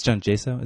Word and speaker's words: John Jaso: Or is it John? It's John Jaso. Is that how you pronounce it? – Yeah, with the Pirates.